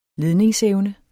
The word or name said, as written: LEDNINGSEVNE